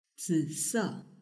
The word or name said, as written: zise